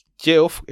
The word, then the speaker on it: Geoff: Geoff, escrito con G, G-E-O-F-F, como Jeff Jones, se pronuncia igual que Jeff, J-E-F-F, porque en distintos lugares de Inglaterra se escribía de distintas formas porque no tenían un acuerdo de cómo se escribían las palabras.